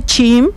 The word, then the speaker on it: chim: Pues en Yucatán se dice chim y se escribe de esta manera. Ajá. Empezamos con la letra C. – La letra C. – H. – H. – I. – I. – I. – Y la letra M.